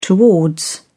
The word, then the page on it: towards: British English